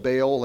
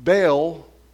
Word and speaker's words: Baal: Baal, or people pronounce it differently, I'll call it Baal, like Baal in a bag of hay, but Baal.